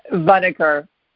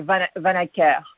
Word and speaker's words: Vanneker: It's Vanneker. – Oh, come on. You have a cooler pronunciation than that. You're in Montreal, correct? – Correct, yes. But the French pronunciation is actually Vanneker.